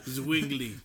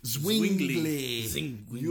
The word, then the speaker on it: Zwingli: I can never say his name. – Zwingli. – Zwingli. Ulrich Zwingli.